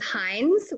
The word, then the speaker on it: Hines: Okay, but also my maiden name is Hines, which rhymes with Bynes.